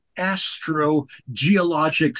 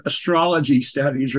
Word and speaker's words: astrogeologic: Geological Survey branch of astrogeologic studies. I pronounce that very carefully because a lot of people have said astrology studies or such.